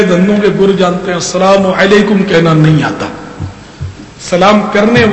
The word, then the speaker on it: السلام علیکم: اس میں بھی لوگ تلفظ کی بڑی غلطیاں کرتے ہیں، اب جیسے کہ سلام علیکم، سلام علیکم، مالیکم سلام، جواب والا بھی پیچھے نہیں ہے، وہ مالیکم سلام کہتا